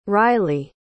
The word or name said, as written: rilye